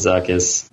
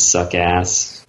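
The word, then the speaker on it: Zuckuss: I mean, Forlom and Zuckus. I mean, everybody. Yeah, everybody always mispronounced his name as Suckass.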